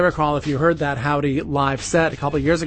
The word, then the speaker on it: Joudy: It is spelled J-O-U-D-Y, but it is pronounced Howdy.